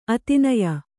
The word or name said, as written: ಅತಿನಯ